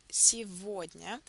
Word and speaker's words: сегодня: You don't hear the letter or the sound г in the middle, as you can see it in the spelling. When the letter г is placed between vowels е and о, it's always pronounced as в sound. So you will say сегодня.